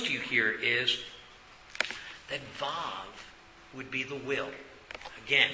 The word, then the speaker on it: Vav: And Vav starts in your Bibles, at least in my Bible, it says W-A-W. And some people will teach it differently how to say this letter. I was taught Vav that they can't, that the Hebrews had the V sound, not the W sound here.